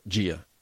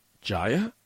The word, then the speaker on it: Jia: They decided to name this beautiful baby girl Gia. But they spelled it J-I-A. Jaya? So whenever they went to the doctor would always mispronounce her name. Commonly, I don't blame them, calling her Jaya.